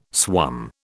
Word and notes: swum: British English

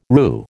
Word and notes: roo: American English

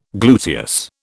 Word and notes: gluteus: British English

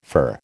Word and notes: fur: American English

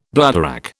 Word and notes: bladderwrack: British English

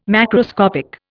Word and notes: macroscopic: Indian

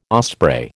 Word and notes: osprey: American English

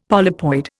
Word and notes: polypoid: Indian

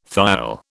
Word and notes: thiol: British English

